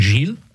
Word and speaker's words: Gilles: In French, I think it would be Saint Gilles. I think is how it would be pronounced, but Giles in English.